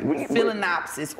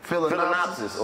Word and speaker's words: Phalaenopsis: Phalaenopsis orchid. – Phalaenopsis orchid.